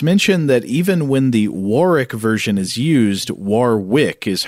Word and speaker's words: Warwick: It was recently mentioned on one of your podcasts that the correct pronunciation for what is spelled Warwick, as in Warwick Davis, is Warwick.